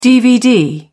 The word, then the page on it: DVD: British English